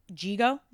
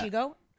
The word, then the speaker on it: Jigo: But, is it Jigo? Is that how? Or Chigo? – I think it's Jigo in the American version.